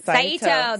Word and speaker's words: Saito: Mr. Sayato? – Saito. That's how it was.